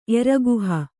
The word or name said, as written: ಎರಗುಹ